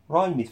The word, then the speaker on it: rhymed: rhymed فاكرين ال H اللي قلت لكم بعد ال R، قلت لكم ال H silent، قلت silent ليه؟ لأنها لا تنطق، فأنت بتقول rhymed prose خلاص كده.